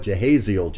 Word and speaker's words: Jehaziel: You know, in other words, believe what Jehiel's just told you. Did I say that right? Jehaziel. Believe what Jehaziel just told you.